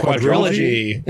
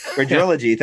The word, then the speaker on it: Quadrilogy: Quadrilogy. – Quadrilogy.